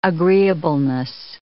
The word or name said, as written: agreeableness